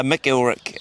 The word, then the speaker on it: Mcilwrick: It's MciLwrick. If I was knighted, I'd be called Sir Ulrich. So the MC just stands for son of Ulrich.